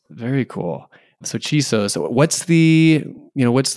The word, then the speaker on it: Chisos: am i saying that right i am right Chisos. Chisos. – Chisos. The, uh, yeah, the Chisos Mountains out in West Texas. – That's ah, correct. that's right.